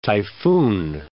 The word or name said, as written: typhoon